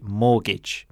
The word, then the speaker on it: mortgage: Notice the pronunciation as well is mortgage, because it is written mortgage, but that's not what we say. We say mortgage.